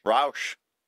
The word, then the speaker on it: Rauch: Rauch.